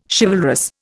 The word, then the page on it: chivalrous: Indian